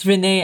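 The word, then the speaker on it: René: The video follows the adventures of Lane Nystrom, who's trying to find her on-screen lover, other Aqua member, Rain Diff. – It's Renee, actually it's Renee. It's Renee, the accent on the second E.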